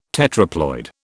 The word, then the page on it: tetraploid: British English